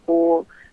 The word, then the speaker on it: Poole: And you can also find me on Instagram as well and YouTube under Maya Poole. That's spelled on YouTube. It's M-I-A, last name P-O-O-L-E.